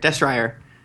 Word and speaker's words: Destrier: when I was reading last week, I googled Destria and found out that it was actually a real thing. – What? – Destrias were really real horses. – That's how you say it? Oh. It's Destrier.